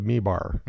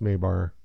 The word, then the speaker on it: Mabar: Okay, so if you look at the worlds of Mibar, Mabar. Spelled Mibar, pronounced Mabar, whatever.